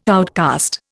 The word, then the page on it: outcast: Indian